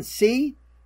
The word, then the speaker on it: Tse: They did have producer and co-writer Vivian C, or T, she spells it T-S-E, but I think they pronounced it C.